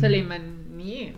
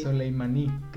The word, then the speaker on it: Soleimani: Que se le ocurrió mandar a matar a un tal Qasem Soleimani. Sí, no sé si pronuncié bien. – Soleimani, sí, creo que sí. – Soleimani, Qasem Soleimani.